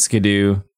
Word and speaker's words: Skiddo: It is pronounced Skidoo, not I don't pick the names, that's just how it's pronounced.